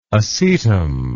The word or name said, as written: acetum